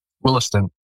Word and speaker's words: Williston: Williston? – Williston.